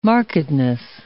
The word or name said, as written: markedness